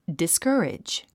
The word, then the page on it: discourage: diskə́ːridʒ; ディスカァレジ